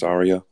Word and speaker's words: Ariya: Pronounced Ariya.